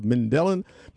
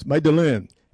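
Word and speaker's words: Medellín: I used to think it was Mandelin, but it's not. It's Mandelin. He infiltrated the Medellin drug gang, a.k.a.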